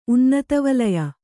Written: ಉನ್ನತವಲಯ

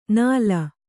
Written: ನಾಲ

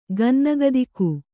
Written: ಗನ್ನಗತಕ

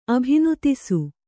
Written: ಅಭಿನುತಿಸು

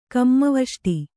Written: ಕಮ್ಮವಷ್ಟಿ

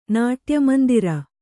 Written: ನಾಟ್ಯಮಂದಿರ

